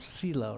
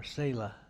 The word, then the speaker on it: Selah: It's called Selah or Selah. Depends how you pronounce it.